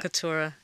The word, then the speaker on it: Keturah: it's the second syllable, Keturah.